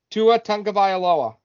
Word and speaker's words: Tua Tagovailoa: Tua Tagovailoa.